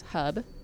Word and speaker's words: Habe: So in December um, of 1969, December 31st, Marina Hub, which is H A B E, it's a German pronunciation. I looked it up, everybody. It's, it's Hub, and she was a 17 year old daughter of a Hollywood screenwriter, and her mom was an actress.